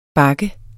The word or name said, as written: bak